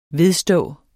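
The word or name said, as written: VEDSTÅ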